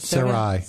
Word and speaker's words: Sarai: God also said to Abram, as for Sarai, your wife, you are no longer to call her, how do you pronounce it? – Sarai. – Sarai, excuse me.